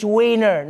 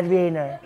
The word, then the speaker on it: Wiener: He called out Canada for not pulling its weight financially, And he demanded that Germany stop pronouncing their W's like V's. You're making Wiener Schnitzel sound way less funny like that. It's Wiener, not Wiener.